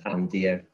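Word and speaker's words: Andeer: So instead of Andrew, he thought it was Anne Deer. So it's just A-N and then spelled like a deer you would see in the forest.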